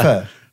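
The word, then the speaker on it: phở: As in the F sound, thank you. – Yeah, well done. Now, interestingly, as I'm sure you know, this is the noodle soup synonymous with Vietnamese cuisine. A lot of customers, when they're in Vietnam, think it is pronounced either pho or phu, but pho is the commonly accepted pronunciation.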